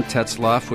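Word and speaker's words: Tetzlaff: My name is John Tetzloff with Tetzloff Yacht Sales.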